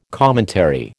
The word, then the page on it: commentary: American English